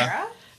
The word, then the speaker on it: Hera: Hera. Rhymes with Sarah. – Yep. H-E-R-A. – H-E-R-A.